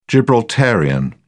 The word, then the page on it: gibraltarian: British English